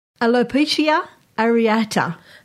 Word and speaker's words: alopecia areata: Alopecia areata. – Oh, that sounds good. – Sounds pretty right to me. – Yeah, Alopecia areata, meaning one or more around bald patches appear suddenly, most often on the scalp.